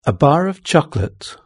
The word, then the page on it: a bar of chocolate: British English